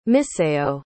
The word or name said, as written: misayo